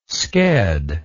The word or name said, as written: sked